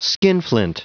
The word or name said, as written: skinflint